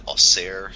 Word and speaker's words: Auxerre: This is Germanus of, and my French is always terrible, but it's spelled AUXERRE, which I'm going to say in my head, Auxerre, something like that.